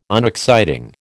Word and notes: unexciting: American English

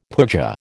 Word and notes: pooja: American English

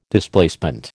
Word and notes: Displacement: American English

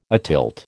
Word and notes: atilt: American English

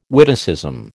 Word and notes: witticism: American English

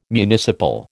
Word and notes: municipal: American English

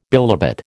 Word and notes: bilobate: American English

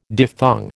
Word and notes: diphthong: American English

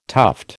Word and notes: tuft: American English

